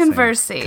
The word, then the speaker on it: Conversi: Before I even introduce you, Ariel, I can never say your last Conversi. – Name. Conversi. I want to say it like really Mexican for some reason.